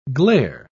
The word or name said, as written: glare